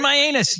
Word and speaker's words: Mianus: M-I-A-N-U-S. – Oh, that can't be the way you pronounce it, though. – Dude. – Mianus.